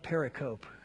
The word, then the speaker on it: pericope: It's spelled like pericope, but it's pronounced pericope.